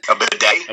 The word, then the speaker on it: bidet: I'm talking about, like, a Ladee, right? Is that how you say it? How do you say it, Ladee? – A bidet? – A bidet, thank you.